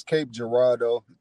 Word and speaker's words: Cape Girardeau: You didn't do too bad on the name, man. It's Cape Girardeau, So you ain't do too bad, man.